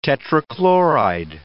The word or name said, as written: tetrachloride